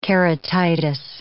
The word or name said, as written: keratitis